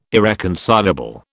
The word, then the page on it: irreconcilable: British English